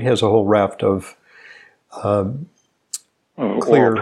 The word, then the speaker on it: Xlear: Your son, Nate Jones, has been on the program talking about clear nasal spray, spelled X-L-E-A-R. The X stands for xylitol, so it's pronounced clear, but it's spelled X-L-E-A-R.